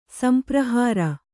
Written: ಸಂಪ್ರಹಾರ

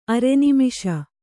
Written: ಅರೆನಿಮಿಷ

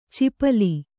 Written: ಚಿಪಲಿ

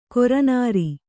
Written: ಕರಿನೀರು

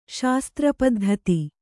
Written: ಶಾಸ್ತ್ರಪದ್ಧತಿ

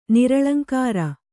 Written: ನಿರಲಂಕಾರ

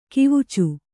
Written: ಕವಿಚು

